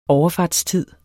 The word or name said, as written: overfartstid